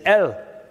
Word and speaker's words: El: And so the Aleph and the Lamed combined, when it pronounced, the pronunciation is El, but it means strong leader or powerful shepherd.